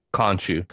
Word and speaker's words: Khonshu: Layla angrily confronts Spectre, who reveals his partner killed Layla's father, Inspector himself, before, how do you say it? Khonshu, yes. Thank you.